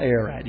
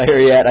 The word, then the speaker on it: Ararat: Mount Ararat, I guess, is how you pronounce it. And it's spelled A-R-A-R-A-T. A-R-A-R-A-T.